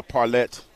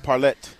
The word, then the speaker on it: parlay: You pronounce it as though it's a French word, but that ends with an E-T. – Parlet.